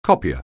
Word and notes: copier: anglais

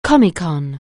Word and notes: Comecon: anglais

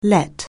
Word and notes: let: anglais

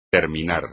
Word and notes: terminar: espagnol

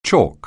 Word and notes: chalk: anglais

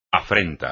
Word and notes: afrenta: espagnol

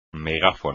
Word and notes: megáfono: Spanish

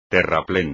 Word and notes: terraplén: espagnol

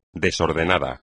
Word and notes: desordenado: espagnol

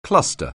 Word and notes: cluster: anglais

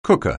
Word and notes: cooker: anglais

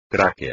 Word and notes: tráquea: espagnol

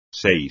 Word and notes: seis: espagnol

